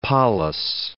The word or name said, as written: polis